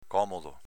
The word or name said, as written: comodo